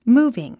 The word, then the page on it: moving: múːviŋ; ムービン